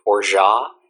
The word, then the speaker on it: orgeat: So it's 2 ounces of bourbon, a quarter ounce of orgeat, which is spelled O-R-G-E-A-T.